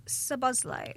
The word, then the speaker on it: Szoboszlai: Well, Dominic Szoboszlai. – Oh, very well pronounced.